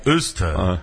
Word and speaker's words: Worcester: Pronunciado localmente como Worcester. – Eles falam Worcester. – E a pronúncia se assemelha com Uster.